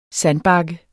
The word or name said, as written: sandbakke